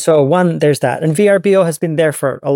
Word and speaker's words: Vrbo: And then so they renamed everything Vrbo, VRBO as in Vrbo. That's kind of how they pronounce it.